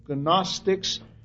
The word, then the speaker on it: Gnostics: They were Gnosticizing heretics and fruits of the Gnostics, G-N-O-S-T-I-C-S, Gnostics. In English, the G does not get pronounced, so we pronounce it Gnostics or Gnosticism.